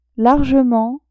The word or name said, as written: largement